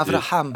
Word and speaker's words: Abraham: Donald Trump, dans son discours à la Knesset, avait d'ailleurs dit est-ce qu'on les prononce Abraham ou Ibrahim, si c'est des accords de paix? Et lui, il était Abraham.